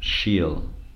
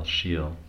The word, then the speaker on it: shil: And this comes from Sanskrit root shil, S-H long I-L, shil, which means to act, to practice, to serve, to worship, to meditate.